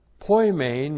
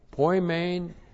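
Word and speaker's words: Poimen (ποιμήν): Poimen means shepherd. So let's go back here. Poimen means shepherd. P-O-I-M-E, long E-N. Poimen is the Greek word for shepherd.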